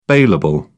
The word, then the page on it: bailable: British English